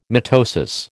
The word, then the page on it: mitosis: American English